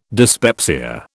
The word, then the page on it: dyspepsia: British English